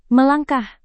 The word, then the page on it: melangkah: Indonesian